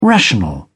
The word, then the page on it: rational: British English